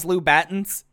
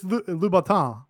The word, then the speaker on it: Louboutin: We're both Southern here, and we haven't heard someone say, did you see them in those Louboutins? No. – I say Gangrel, and I you know, it's, I mean, it's Louboutin. – Louboutin. I mean, which is how he pronounced it.